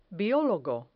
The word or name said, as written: biologo